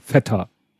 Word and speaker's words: Feta: Also ich habe tatsächlich den griechischen Wikipedia-Artikel zu Schafskäse gefunden und da ist ein Aussprachebeispiel und da wird das wirklich Feta. Das heißt nicht mhm. Fete.